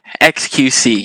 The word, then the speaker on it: xQc: Is it XQC? Is that how you pronounce it? – XQC, yeah. Okay. At least that's how I understand it, right? Like, just reading it phonetically as letters, XQC.